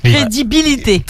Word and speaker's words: crédibilité: Non, alors tu as oublié une syllabe, tu as oublié le lit. – Crédibilité. – C'est comme débilité. Cyril, tu retiens, c'est comme débilité. Crédibilité, débilité, c'est pareil.